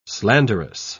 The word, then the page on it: slanderous: British English